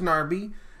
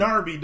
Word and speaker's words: Gnabry: I don't know how to pronounce that name. – Gnabry, dog. Gnabry. – I'm down for Gnabry. – Don't say Gnabry. – He cleared it towards Gnabry. – Is it a Gnat too? – Yeah. – Okay. – You don't ever make a word called GN and have a silent G. It's stupid. A silent G is stupid.